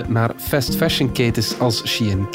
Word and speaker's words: Shein: Maar bon, Shein, zo zeg je het. Ja. – Het komt van She Inside. She Inside, voilà.